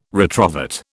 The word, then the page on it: retrovert: British English